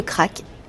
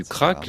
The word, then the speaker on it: craic: On pourrait croire que les Irlandais sont les rois du crack, les rois du divertissement, mais en réalité, beaucoup de mots qui ont trait à l'amusement viennent de l'anglais. – En anglais, crack s'écrit C-R-A-C-K, en irlandais C-R-A-I-C.